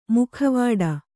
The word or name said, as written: ಮೊಕವಾಡ